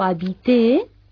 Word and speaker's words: habiter: Por ejemplo, el verbo habiter, con B, se pronuncia como barco en francés.